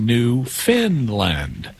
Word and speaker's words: Newfoundland: It's spelled Newfoundland, but it's pronounced Newfinland, even though it's not located in Scandinavia.